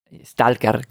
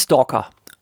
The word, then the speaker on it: Stalker: Stalker, genau. So spricht man das aus, nicht Stalker. Stadtger.